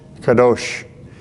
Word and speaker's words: Kadosh: Kadosh.